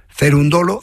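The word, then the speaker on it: Cerúndolo: Este juego, aunque lo haya ganado Cerundolo, que lleva acento en la U y tal,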